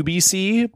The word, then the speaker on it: Ubyssey: And it's also very bad because it is just pronounced the UBC, but of course it looks, when it's written, it looks like the UBC, like the Odyssey.